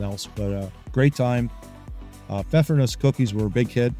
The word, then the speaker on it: Pfeffernuss: It's Pfeffernuss. Pronounced with the P in front.